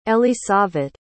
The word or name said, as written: elisavet